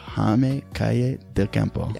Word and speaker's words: Calle del Campo: How do you say your last name? – Jaime Calle del Campo. – Jaime Calle del Campo. Yeah, perfect.